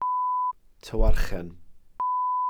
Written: tywarchen

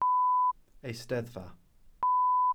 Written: eisteddfa

